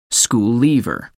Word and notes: school leaver: American English

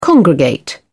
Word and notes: congregate: British English

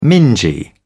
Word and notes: mingy: British English

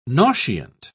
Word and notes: nauseant: British English

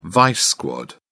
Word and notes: vice squad: British English

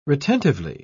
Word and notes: retentively: British English

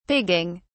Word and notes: pigging: British English

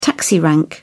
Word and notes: taxi rank: British English

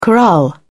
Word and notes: corral: British English

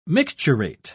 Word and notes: micturate: British English

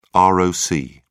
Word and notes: roc: British English

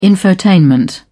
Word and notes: infotainment: British English

